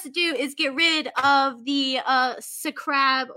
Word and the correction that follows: scarab: Sa- scarab.